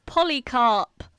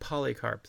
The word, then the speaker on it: Polycarp: Saint Polycarp, though. Okay, so is it spelled like... – It's spelled like it sounds. Like poly as in polyamorous. Ooh. Carp as in the fish.